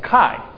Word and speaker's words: chi: The letter X is pronounced chi. C-H-I.